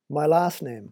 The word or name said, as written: my last name